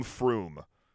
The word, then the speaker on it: Frome: You pronounce Frome Froom.